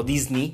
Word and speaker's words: Disney: Tal vez, puede ser, que si se hace mucho ruido, pues Disney, o Disney, como realmente se pronuncia, em, decida cambiar la producción o, o, a la actriz.